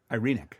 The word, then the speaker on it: irenic: Some of Beam's words, how do you say that word? Irenic? – Irenic.